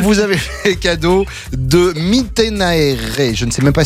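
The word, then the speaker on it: Mittenaere: Iris Mitenaere. Je crois que c'est comme ça que ça se prononce. On me fait signe.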